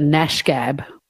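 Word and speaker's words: gnashgab: Got Or you, Gareth, might be a bit of a Nash Gab. And that starts with a G, a silent G.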